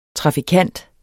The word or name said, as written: trafikant